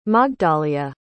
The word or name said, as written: magdalia